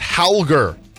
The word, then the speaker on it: Holger: It's actually supposed to be pronounced Halger. – Oh, Rune. Halger. – It's spelled H-O-L-G-E-R. Halger Rune.